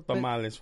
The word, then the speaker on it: tamales: No, you said tamale. You said tomale. – Yeah. – You said it wrong.